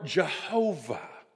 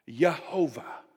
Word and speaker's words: Jehovah: And today we would pronounce that word in two different ways depending on which century you're from. We would either say that that name is the name Yahweh or Jehovah. Jehovah.